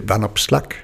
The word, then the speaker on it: Van Opslag: Det er Van Opslag, men det er jo egentlig en forfransket udtale. Efternavnet er oprindeligt hollandsk okay. – eller flamsk, og så er jeg lidt i tvivl om, hvordan det så udtales. Men jeg vil tro, det er noget i retning af Van Opslag. Men min familie er fra den fransktalende del af Belgien, så det er en tillagt fransk udtale, jeg har.